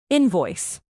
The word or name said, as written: invoice